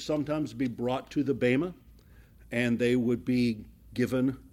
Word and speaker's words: Bema: This is where we come to the reward bench, or it's called in the in the scriptures called the Bema, B E M A, long E. B E M A, Bema.